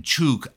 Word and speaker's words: Chuuk: This was the Truk Atoll in the Carolines, as it was called. That's actually a corruption of the native pronunciation Chuuk.